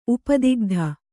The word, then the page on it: ಉಪದಿಗ್ಧ: upadigdha